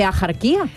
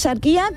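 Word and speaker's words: Axarquía: Escribimos Sarquía con X, sí. ¿Vale? Pero ¿cómo lo pronunciamos? ¿Ajarquía? No, yo no conozco a nadie, a mí que me registren, pero yo no conozco a nadie que diga ajarquía. Ya puede venir la RAE a decir misa canta.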